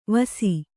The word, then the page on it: ವಾಸಿ: Kannada